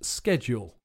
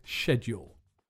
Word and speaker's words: schedule: In American English, it's pronounced schedule. Lots of British people pronounce it that way too, but in the dictionary, it says that the British pronunciation is schedule.